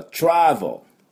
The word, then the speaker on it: travel: まあ、実際はですね、トラベルは travel。で、インシュランスは insurance。 insurance。という発音します。 travel はダブルシーンの tr, tr, tr。それと、おみゃーの音に v です。 v, v。で、 v。で、 vel, vel, vel。